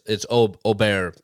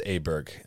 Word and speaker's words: Åberg: Yes, it's Åberg, please. Put a little respect on Neil and the American pronunciation is Åberg, and Ludvig has said so himself.